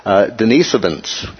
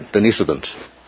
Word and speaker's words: Denisovans: Denisovans, okay? So that's how you pronounce that. Like Denise Evans, right? Denisovans.